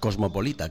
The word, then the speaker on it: cosmopolita: En Nueva York es que la gente es muy moscopolita. – ¿Cómo? – Moscopolita. – Moscopolita. – ¿Se dice así? – Sí. Más o menos. Cosmopolita, querrá usted.